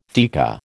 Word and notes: theca: American English

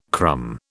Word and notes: crumb: British English

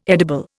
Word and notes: edible: Indian

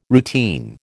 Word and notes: routine: American English

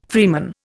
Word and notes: freeman: Indian

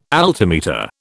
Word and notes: altimeter: British English

